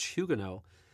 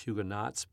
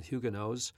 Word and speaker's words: Huguenot: She was a French Huguenot. Others might know them as French Huguenots, but that's not the right pronunciation. Huguenots. – We say Huguenot.